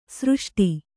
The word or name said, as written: ಸೃಷ್ಟ